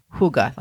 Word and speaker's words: Hygge: Hygge is a Danish term pronounced Hygge, like H-O-O-G-A, but it's actually spelled H-Y-G-G-E.